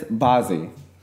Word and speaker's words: Bazzi: And so the first one that she got for me was from at Bazzi, B-A-Z-Z-I. It says Bazzi. Bazzi, is that how you say it? – Yeah, he's famous.